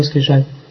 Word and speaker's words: скрижали: Там наверху у него Субхану Аталию хранимой скрижали. Скрижали или скрижали? Скрижали, по-моему. – Скрижали. – А? – Скрижали. – Куда ударение ставится? Скрижали, да?